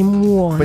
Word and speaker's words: muoni: Sì, i muoni. – Pensavo fosse una pronuncia sbagliata.